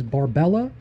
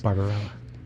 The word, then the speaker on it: Barbarella: VCR and only a couple of movies such as Barbella and Marathon Man, respectively 1968 and 1976. – Barbarella.